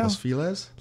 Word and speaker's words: Los Feliz: I would say Los Feliz. How do you say it? – Los Feliz? – Los Feliz.